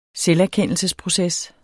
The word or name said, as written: selverkendelsesproces